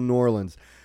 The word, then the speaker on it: New Orleans: It's New Orleans. It's one word, one syllable, New Orleans.